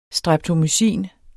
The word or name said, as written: streptomycin